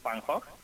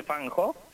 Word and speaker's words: Van Gogh: Bueno, pues se trata de la historia y trayectoria de este pintor neerlandés, o de Países Bajos, Vincent Van Gogh. Es como se pronuncia. – Ah, Vincent Van Gogh, ajá, Vincent Van Gogh. – Uy, yo quiero oír, quiero oír, ¿eh? – Sí, Van Gogh es como se pronuncia realmente el apellido, pero bueno.